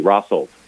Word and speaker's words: Rawsalt: So the first tell is that you pronounce it Rossel and not Rossel. – Oh, yeah. – So that's correct pronunciation is like spelling raw. R-A-W-S-A-L-T, Rossolt. That's Rossolt. How you pronounce it.